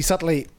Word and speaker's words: subtly: Delusions, because he feels he has some definite and predictable control over these brackets, which he subtly. I didn't read the word. Well, it was the right word, but I pronounced the B in subtly. Like a bad man. Which he subtly recognizes as creations of his own.